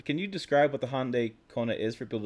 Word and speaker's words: Hyundai: So the Hyundai, which rhymes with Sunday, Kona, is a new small sport utility vehicle.